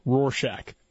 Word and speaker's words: Rorschach: No, Rorschach. I think you nailed it. Yeah. – How do you pronounce it? – Rorschach.